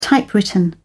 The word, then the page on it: typewritten: British English